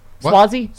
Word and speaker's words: Suozzi: And that the fact that Republicans killed that bipartisan deal put them over the edge to vote for Tom Suozzi. – Wearing my Blue Nami t-shirt this morning. How do you say it? Suozzi? – Suozzi, yeah.